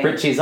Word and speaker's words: Prizzi: It's spelled P-R-I-Z-Z-I. – But that's not how they say it in the movie. Prizzy.